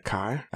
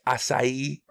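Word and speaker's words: açaí: I know it's a berry, but I was corrected multiple times at work by hipsters that I worked with, insisting that it is pronounced acai. – Acai. – And I was like, I'm not saying that. That sounds silly to me. And they're like, well, that's dumb. That's how it's said.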